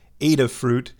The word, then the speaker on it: Adafruit: I'm at one of my favorite websites, Adafruit, or it might be more accurate, more accurately pronounced, Adafruit. But I kind of like Adafruit better than Adafruit, because, so, what'd you do this morning?